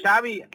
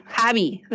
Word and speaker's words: Xavi: Xavi. – Bueno, es Xavier, que Xavier. – ¿Con J o con X? – Con X. Sí. Xavi, ah, pero se pronuncia Xavi, pues, es que quiere ser original.